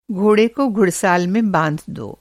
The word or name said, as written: घुड़साल